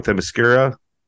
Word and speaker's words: Themyscira: Next one is Paradise Lost, which is apparently like kind of based in I always pronounce this wrong, Themyscira.